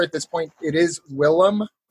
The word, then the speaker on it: Willem: He started to go by Willem in college, which is the Dutch pronunciation of his name, I believe.